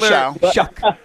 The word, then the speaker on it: Shough: And I brought up what Tyler Shough is doing. – Shuck. – And I know what their record is. – Get his name right. – Tyler Shough.